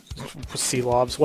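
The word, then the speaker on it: Silovs: Shilovs, is that how you say it? – Sealovs, whatever. – Sealovs, yeah.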